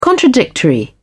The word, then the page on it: contradictory: British English